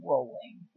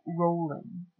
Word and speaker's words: Rowling: One of our Twitter followers asked how you pronounce her name, the author's name. – What, J.K. Rowling? – It's Rowling. – It's Rowling? – From her own mouth, I found a video. – Rowling.